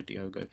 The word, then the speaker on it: Diogo: Yeah, it was a bit of both, but at the same time, I think Diogo Costa, should I say his name, not Diego, Diogo.